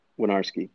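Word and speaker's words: Winiarski: So the W-I-N-I, the I is silent. – Winarski.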